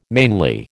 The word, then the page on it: mainly: American English